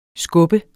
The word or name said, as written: skub